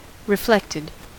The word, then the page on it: reflected: American English